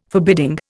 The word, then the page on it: forbidding: Indian